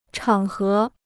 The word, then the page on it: 场合: chǎng hé